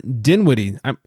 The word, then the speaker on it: Dinwiddie: And the Nets, Spencer Dinwiddie. Is that how you say his name, Dinwiddie? I never heard of this guy. – Yes, Spencer Dinwiddie.